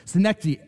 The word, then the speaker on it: synecdoche: Okay, I'm going to spell that for you. I have trouble even pronouncing it, but I'm going to spell it for you, and then I'm going to try to pronounce it multiple times as we go through the sermon. So synecdoche, S-Y-N-E-C-D-O-C-H-E, synecdoche. Okay?